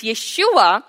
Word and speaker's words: Yeshua: Yes, in English, Jesus, but in the Aramaic, the original pronunciation, it's Yeshua,